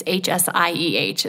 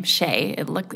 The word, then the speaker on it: Hsieh: And if you just even Google, it's Hsieh is his last name, Hsieh. It's pronounced differently than it appears.